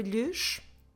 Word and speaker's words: lhes: Lhes, como se escreve lhes? L-H-E-S.